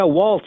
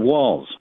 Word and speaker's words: Walz: So, I was really upset that they kept pronouncing our governor's name wrong. Did you see that? – Yeah, Walz instead of Walz. – Yes, what was that about? There's no T in the man's name.